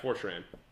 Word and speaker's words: 4chan: Yeah, 4chan.